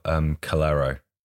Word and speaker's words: Calero: I mean, we talked before about Calero. And did I pronounce his name correctly? – Yeah.